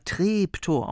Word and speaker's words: Treptow: Das wird zwar Treptow geschrieben, aber Treptow ausgesprochen. Das ist im Osten so. – Das heißt tatsächlich Treptow.